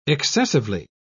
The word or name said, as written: excessively